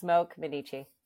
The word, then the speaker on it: Smoak Minnici: Did I get that right? – Yes, Smoak Minnici.